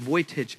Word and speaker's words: Vujicic: His last name is spelled V-U-G-I-C-I-C. So I don't know how you get Vujicic out of that, but that's how you pronounce his name.